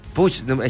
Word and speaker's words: Puigdemont: Y Carles Puigdemont busca... Ya no sé cómo pronunciar este calle, ¿verdad? – Puigdemont. – Es que uno le dice Puigdemont, Carles, otro le dice Charles.